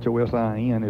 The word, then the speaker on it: Chosin: As a matter of interest, for what it's worth, Chosin, Chosin, is the Japanese name for that reservoir. For our Korean listeners, it is Changjin. Changjin Reservoir is the Korean pronunciation.